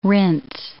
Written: rinse

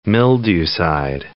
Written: mildewcide

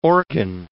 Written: organ

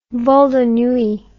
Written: vol de nuit

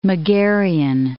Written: Megarian

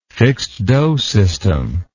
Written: fixed-do system

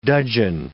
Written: dudgeon

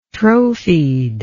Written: trophied

